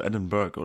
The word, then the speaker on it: Edinburgh: Edinburgh. Edinburgh, so wie die Briten Bro aussprechen. – Edinburgh.